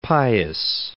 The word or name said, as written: Pius